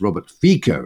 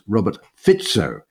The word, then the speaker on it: Robert Fico: So for example, last week when we were talking about Slovakia, we were referring to the new or the about to be new prime minister as Robert Fico. It turns out his name is actually pronounced Robert Fico.